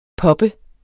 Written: pop

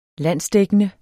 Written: landsdækkende

